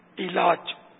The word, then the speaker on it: علاج: علاج، علاج، درست تلفظ ہے علاج، پین کے نیچے زیر۔